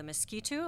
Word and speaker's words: Miskito: Well, you mentioned Nicaragua, and that's another thing I wanted to talk about, the relationship with the groups of indigenous people, the Miskito. Am I saying that right? – Correct.